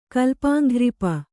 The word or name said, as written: ಕಲ್ಪಾಂಘ್ರಿಪ